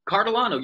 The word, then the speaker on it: Cartolano: Cartolano, you're pretty close.